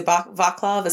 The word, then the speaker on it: Vaclav: And I might be butchering his name, but it's V-A-C-L-A-V, so Vaclav is how I would assume. – V-A-C-L-A-V, Vaclav, yeah.